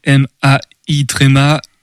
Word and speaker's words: Maïeutique: Maïeutique, on va l'appeler quand même, c'est Maïeutique. Quasiment comme ça se prononce, c'est juste que c'est pas un Y en fait, tout exactement.